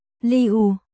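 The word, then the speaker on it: Liu (六): Số 6 phát âm là Liu, tuy nhiên cách đọc của từ này lại có phần tương đồng với chữ New phát âm là Niu.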